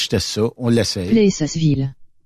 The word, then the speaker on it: Plessisville: Plessisville. – Ah, il y a toujours les dedans. – Il y a toujours l'S.